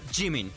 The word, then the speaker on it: Jimin: Cuando vean consonante J, J, tenemos que cuidar. Ah, este se pronuncia como J en español o estilo inglés como J. Juice, jugo. Ok, entonces es Jimin.